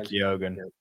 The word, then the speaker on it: Keoghan: It's Barry Keoghan. I keep getting his surname wrong. – Barry Keoghan.